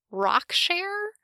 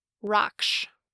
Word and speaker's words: Raksh: It's spelled R-A-K-S-H, and the one pronunciation guide I could find online pronounced it Rockshare, which I'm pretty sure isn't right. Raksh. I really want to call him Rakash, which is a name from Tamora Pierce. Let's focus. Raksh.